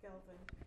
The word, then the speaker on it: Gelvin: Gelvin. – Gelvin, G-E-L-V-I-N. Mm-hmm. And Foti is spelled F-O-T-I? – Yes.